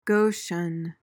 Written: goshen

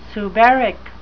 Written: suberic

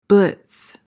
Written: blitz

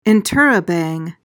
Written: interrobang

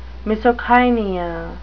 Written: misocainea

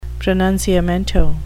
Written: pronunciamento

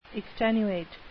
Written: extenuate

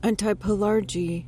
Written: antipelargy

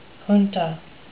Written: junta

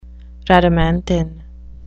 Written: rhadamanthine

